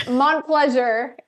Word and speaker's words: Montplaisir: It's Montplaisir. It's Montplaisir, a French last name, so it throws everybody for a loop.